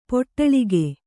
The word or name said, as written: ಪಟಳಕ